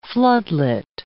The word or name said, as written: floodlit